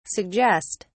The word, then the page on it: suggest: British English